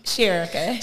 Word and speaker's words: Sherika: Let me say your name right, because I said it wrong before. Say your name. – Sherika.